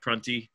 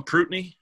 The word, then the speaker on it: Prunty: Some of that showed up in those freshmen like Prunty, which doesn't Les call him Prutney every time? – You never know how he's going to pronounce it. – Oh, he's done it right? – Yeah. – Okay. I feel like I only hear him say Prutney, and it makes me question myself, because I'm like, have I been pronouncing this thing wrong?